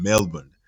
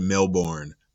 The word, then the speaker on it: Melbourne: Luca comes to this episode from Australia, from Melbourne. So some of us pronounce it like Melbourne, but it's Melbourne, right?